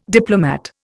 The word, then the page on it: diplomat: Indian